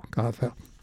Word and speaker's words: Gade: It's shown in the advice column, if you will, of pronouncing composers' names as making the D especially soft, just almost a TH, so Gade. But G-A-D-E is the spelling of the name,